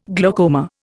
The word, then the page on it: glaucoma: Indian